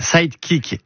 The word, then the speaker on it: Sightkick: Sidekick, S-I-G-H-T-K-I-C-K. Voilà la prononciation et l'orthographe.